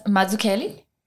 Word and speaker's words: Malucelli: Thomas Malucelli.